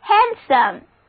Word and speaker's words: handsome: 我们再来看这个单词 handsome, H-A-N-D-S-O-M-E, 中间的字母 D 不发音，跟我读这个单词 read off me 就是 handsome。 handsome 是熟练的，漂亮的，英俊的。我们知道 S-O-M-E, some 是形容词后缀，在中世纪英语中 handsome 就是顺手的，好用的。